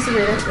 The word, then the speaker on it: Syreeta: Sincerely Sarita, that's who I am.